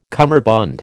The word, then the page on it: cummerbund: American English